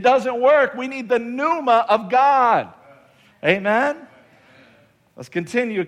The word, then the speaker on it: pneuma: Someone say pneuma. The P is silent here, pneuma, and it means a current of air, a blast of breath, a strong breeze.